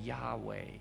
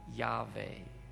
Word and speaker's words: Yahweh: Now some pronounce the name Yahweh or Yahweh, although in many traditions the name isn't even pronounced because it's considered so sacred, so mysterious, so holy.